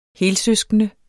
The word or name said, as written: helsøskende